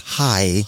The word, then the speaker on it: Ai: After that, it says he built an altar there, and then he went south along his way, and he stopped between Bethel and Hai, or Ai. It's actually pronounced Hai.